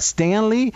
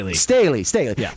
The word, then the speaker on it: Staley: Because if you look at, what was it, Stanley, the D guy, defensive coordinator last year that's now with the Chargers, is that how you pronounce his last name? – Staley.